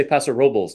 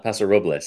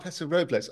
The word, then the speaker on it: Paso Robles: The locals say Paso Robles, not Paso Robles. So, I do speak Spanish, and it took me years to learn to mispronounce it correctly. – Can you say it again? So, you know, I get it wrong. – Paso Robles. So it Paso rhymes Robles. With like nobles.